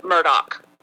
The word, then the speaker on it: Murdaugh: Okay, if I am in a formal setting and I would like to sound my best, I would say, this is Mr. Murdoch. If I am talking with my buddies as I regularly do, it's Murdoch. – Murdoch. – Murdoch. All right. What about Alec, the pronunciation? – A-L-E-X.